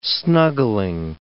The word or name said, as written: snuggling